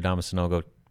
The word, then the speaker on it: Adama Sanogo: Yeah, you can pronounce it Adama, so not going to happen. Because things took a pretty drastic turn over the weekend. Obviously, Adama Sanogo, four-star, top 50 center out of the Patrick School out in New Jersey.